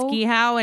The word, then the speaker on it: Gihuo: I think it's Gihow, and it's it's Gihow? from G I H U O.